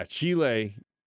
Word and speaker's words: Chile: Yeah, Chile.